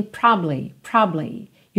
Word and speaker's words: probably: Só que quase sempre em letra de música, ela realmente é omitida. Então você tem probably.